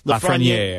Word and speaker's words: Lafreniere: that if you would add Lafreniere, is that how you say it? – Lafreniere, I believe.